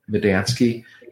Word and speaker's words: Medansky: They can contact me through the website, or they can send it to my private email, which is David Medansky. And my last name is spelled like 3 words, me, Dan, and Sky. Just sounds Polish.